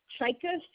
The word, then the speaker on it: Czajka: Chika, C-Z-A-J-K-A.